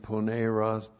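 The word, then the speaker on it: poneros (πονηρός): Been slandering them with logos poneros, L-O-G-O-S, and poneros, P-O-N, long E-R-O-S.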